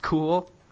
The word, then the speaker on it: cul: It's actually spelled C-U-L, but it's pronounced cool, and it means shit in Catalan.